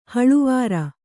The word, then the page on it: ಹಳವಾರ: kannada